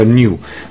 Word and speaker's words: NU: Ora, quando vi dico new jazz music, in realtà, per farvi capire che è diverso, è scritto NU e si pronuncia new, ma ha chiaramente le tonalità sotto.